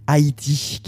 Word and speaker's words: Haiyti: Haïti, qui s'écrit H-A-I-Y-T-I, rappeuse du quartier San Paoli à Hambourg, quartier qui est plus célèbre pour le football antifasciste que pour le rap.